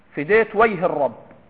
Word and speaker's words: فديت ويه الرب: ومن طرائف هؤلاء البدو على حسن معتقدهم في شيء مما سأبين، أنهم كانوا يقولون على لهجتهم: الرب فديت ويه الرب، فديت ويه الرب، هذه لهجة بدو الإمارات، ينطقون الجيم ياء، فديت ويه الرب، يعني معناها لغة جعلت فدا وجه الرب، فيعبرون يقولون الرب فديت ويهه.